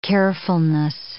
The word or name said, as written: carefulness